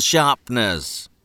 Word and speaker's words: sharpener: Take out your rubbers and pencil sharpeners. – The word sharpener only has 2 syllables when we say it.